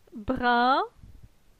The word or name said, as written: Brin